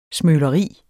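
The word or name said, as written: SMØLERIER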